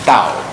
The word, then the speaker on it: Tao: Not Tao. Okay, Tao is wrong. Not T. Matter of fact, you know, the better translation is D. You know, Tao.